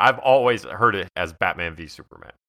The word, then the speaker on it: Batman v. Superman: Are you supposed to say Batman v. Superman? Or does the V represent versus? Should we be saying versus? How do you pronounce this? – I think it's Batman v. Superman. I think that's definitely the intended title.